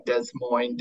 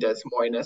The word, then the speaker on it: Des Moines: because everybody pronounces Des Moines wrong, Des Moines, Des Moines. So it started off with, you know, the S's are silent, and then we would pair that with like a noise word.